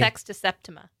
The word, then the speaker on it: Sexta Septima: Let me see if I can say this right. Sexta Septima. S E X T A. – Sexta Septima. – Oh, Sexta Septima. Perfect.